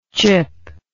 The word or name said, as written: chippable